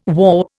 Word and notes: warp: Indian

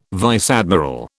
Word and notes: vice admiral: British English

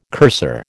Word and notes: cursor: American English

